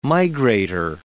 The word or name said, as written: migrator